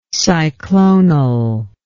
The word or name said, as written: cyclonal